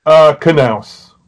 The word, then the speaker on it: Knaus: Knaus. – Okay, you do pronounce the K.